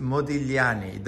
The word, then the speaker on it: Modigliani: And so it's By the way, GL in Italian is Lieu. So it's just Modigliani. The G is silent. – Modigliani, huh? – Modigliani.